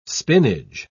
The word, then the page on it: spinage: British English